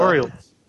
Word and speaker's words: Orioles: Oh, the Orioles. – Areola. – Orioles. they're just a little birdies.